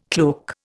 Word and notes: cloak: Indian

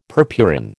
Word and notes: purpurin: American English

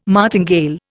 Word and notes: martingale: Indian

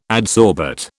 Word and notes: adsorbate: British English

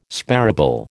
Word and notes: sparable: American English

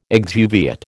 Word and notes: exuviate: American English

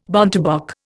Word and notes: bontebok: Indian

